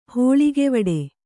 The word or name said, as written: ಹೋಳಿಗೆವಡೆ